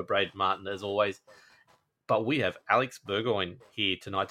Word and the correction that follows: Bergeron: Did I pronounce that right? Just off the start. – Almost. Bergeron is my last name. – Bergeron.